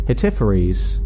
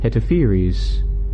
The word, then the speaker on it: Hetepheres: How would you pronounce that? H-E-T-E-P-H-E-R-E-S. Hetepheres. Hetepheres. Hetepheres. That's what I'm going with.